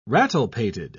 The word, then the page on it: rattlepated: British English